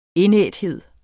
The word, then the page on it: indædthed: [ ˈenˌεˀdˌheðˀ ]; [ ˈenεdˌheðˀ ]